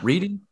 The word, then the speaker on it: Redding: When Donkey started singing Otis Redding. Is that how you pronounce it? Redding? – Yeah. – Reading? Yeah. – Redding.